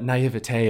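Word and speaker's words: naïveté: Am I being pretentious by saying naivete.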